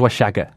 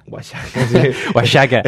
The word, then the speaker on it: Oaxaca: Antes yo recuerdo que la tuna de Oaxaca, no sé cómo, se escribe así, pero no sé cómo se pronuncia. – Oaxaca. No, ¿Es con X? – Es con X. – Entonces Oaxaca. – Oaxaca.